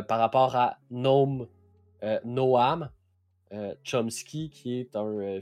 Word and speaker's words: Noam: Puis eux autres, ils ont fait un jeu de mots avec son prénom, Noam, Gnome, que quand tu le prononces en anglais, ça sonne comme un gnome.